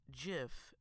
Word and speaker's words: GIF: And I quote, he said, it's pronounced JIF, not GIF.